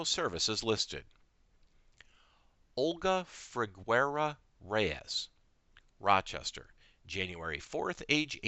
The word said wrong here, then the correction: Figueroa: I believe her middle name is pronounced Figueroa.